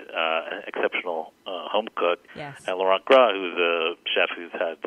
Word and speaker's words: Laurent Gras: Taste test Mitchell Davis and Laurent Gras' Pigs in a Blanket? – I think you get $1 million just for pronouncing Laurent Gras' name so well.